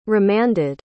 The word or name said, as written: remanded